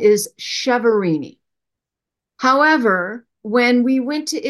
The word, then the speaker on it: Chiaverini: So the correct pronunciation is Chiaverini, yeah, like Chianti. The CH is pronounced.